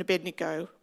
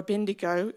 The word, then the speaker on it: Abednego: Of course, many of you would know the story of Shadrach, Meshach, and Abednego, or Abendigo if you're Australian. it's the uh, classic mispronunciation every time.